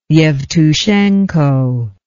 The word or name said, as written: evtushenko